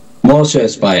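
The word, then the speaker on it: Mosfellsbær: Allora, qual è la pronuncia corretta? – Mosfellsbar. – È però uguale, via. No? – Ci siamo abbastanza.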